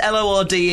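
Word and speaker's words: Lorde: It's L O R D E. The New Zealand guy is very fashionable. – It's Lord, isn't it?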